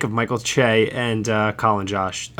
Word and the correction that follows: Jost: Colin, I can't say it right. Colin Jost, thank you.